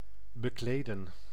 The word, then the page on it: recouvrir: France; [ʁǝ.ku.vʁiʁ]